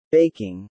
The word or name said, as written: baking